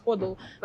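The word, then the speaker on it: HODL: А человек все хранил в эфириуме, и вот есть такой термин ходл, он даже ко мне правильно там произносится ходл, как холд только ходл.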